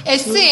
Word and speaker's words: 舒服: 舒服。